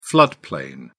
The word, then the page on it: floodplain: British English